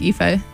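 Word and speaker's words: Aoife: Maybe it's Afe O'Donovan. I feel like I pronounced her name incorrectly, but... – I think Afe. – I think it is Afe.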